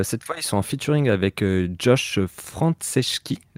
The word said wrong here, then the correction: Franceschi: Non, attends, je le prononce mal. Josh Franceschi, qui est le chanteur de Yumiatix.